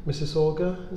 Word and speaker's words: Mississauga: You've come over from Mississauga, is it, in outside Toronto, is that how you pronounce it? – That's right.